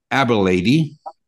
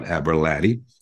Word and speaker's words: Aberlady: Then he closed that and he started the world famous Ducks Inn in Aberlady. I finally learned how to say it, not Aberlady.